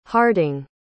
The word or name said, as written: harding